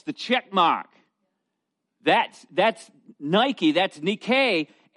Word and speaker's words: Nike: The word for victory is the Greek word V-I-K-N. I'm not sure how you pronounce it in Greek, but the transliteration is N-I-K-E. Nike. Now, it's pronounced Nike, N-E-E hyphen K-A-Y.